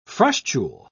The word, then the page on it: frustule: British English